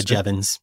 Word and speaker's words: Jevons: So you discuss a paradox, and I don't want to butcher the name, but is it Jevons? Paradox? Is that how you pronounce it? – Yeah, Jevons. Yeah.